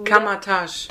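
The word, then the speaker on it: Kamar-Taj: Naja, aber er wird ja dann verfolgt und jemand bringt ihn nach Kammertasch. Kammer, Kammer, wie wird das ausgesprochen? – Nee, sag ruhig. – Wie Nee, ich hab's ja schon einmal gesagt, jetzt bist du. Kamatage. Dankeschön.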